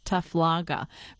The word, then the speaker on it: Taflagra: That's Dr. Maria Taflagra, I'm going to say it correctly, Taflagra, from the Australian National University, speaking there with Ollie Wickham.